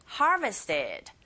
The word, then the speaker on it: harvested: That we harvested. Harvested. 앞부분에 강세를 꽝 주시면 됩니다.